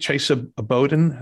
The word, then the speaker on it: Chesa Boudin: But uh, news to me is I think Chesa Boudin is how uh, the DA's name yes. is, is pronounced.